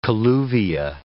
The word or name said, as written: colluvia